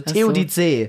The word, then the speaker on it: Theodizee: Und es wird wie Theo die und dann ZEE, e, also Theodizee.